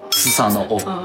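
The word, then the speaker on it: スサノオ: 苏萨的王在日语里叫斯萨诺欧。我们在日本神话一起讲过啊，日本神话有两个核心的神，一个叫天照大神，一个叫须佐之男。我说须佐之男这个神啊，名字好奇怪啊，人都叫什么神，这个神那个神，他叫须佐之男。须佐之男日语怎么读？斯萨诺欧。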